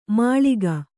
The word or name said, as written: ಮೊಳಕೆ